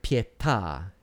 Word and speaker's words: pietà: So a word like pietà has an accent as well.